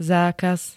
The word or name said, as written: zakaz